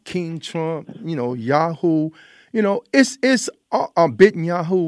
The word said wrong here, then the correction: Netanyahu: whatever you however you pronounce his name. It's Netanyahu. – Almost yeah, Netanyahu. Okay, thanks for correcting me.